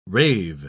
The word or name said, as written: rave